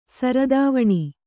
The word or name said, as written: ಸರದಾವಣಿ